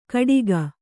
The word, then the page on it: ಕಟಿಗ: kannada